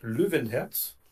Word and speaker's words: Löwenherz: Faut souligner que Löwenherz, ça se prononce Löwenherz, et que M.